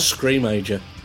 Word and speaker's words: Screamager: I've always said Screamager. – Yeah, Scream. It's meant to be Teenager. – That's what it? – I thought. – Like a pun. I just said it wrong.